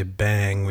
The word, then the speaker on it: Beyng: Reading through the text, I feel like I haven't reminded everybody yet that when we say bang, we are pronouncing B-E-Y-N-G, which is Heidegger's alternative spelling of B-E-I-N-G. And it's kind of a spatio-temporal event type of being, so it's different than B-E-I-N-G.